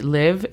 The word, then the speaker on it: live: La única sugerencia que te haría es recordar que la pronunciación de live es más con una E que con una I.